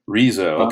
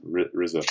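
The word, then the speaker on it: Riso: Rizzo. Okay. I pronounced it wrong. Rizzo.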